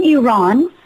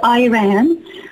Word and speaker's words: Iran: I was born in Iran. I guess it's known mostly in the U.S. as Iran, but it's pronounced Iran.